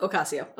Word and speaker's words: Ocasio: How do you say her last? – Ocasio. – Ocasio. There we go.